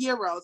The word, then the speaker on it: gyro: And then even aside from Harold's, like things like Pizza Puffs and things like Italian Beefs and, you know, Gyros and Euros, however you pronounce it, the proper pronunciation is Euro, for being honest.